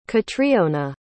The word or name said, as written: katriona